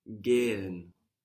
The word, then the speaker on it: gehen: Gehen. – Gehen. – Gehen. – This is spelled G-E-H-E-N. And that H just stretches the vowel out.